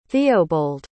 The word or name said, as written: theobold